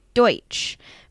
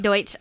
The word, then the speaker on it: Deutsch: Look at you pronouncing that word correctly. – I know Mennonites. – I'm talking about Deutsch. – Deutsch, oh. To give you a better understanding, the Amish are a bifurcation of this group.